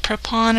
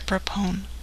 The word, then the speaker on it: Prepon: How do you say it? Prepon or Prepone? Laura? – Prepon, I think is how it's pronounced.